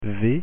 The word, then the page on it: v: French